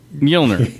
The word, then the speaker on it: Mjolnir: Prowl's crest flies back into Outback's hand like Morjorn... I always say that wrong. – Mew Mew. – Like a... – Mjolnir. – Mjolnir, thank you.